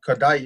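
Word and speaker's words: Kodály: That one's I, I forgot what the uh, Kodai you said right Kodai. Yes, that's how you pronounce yeah.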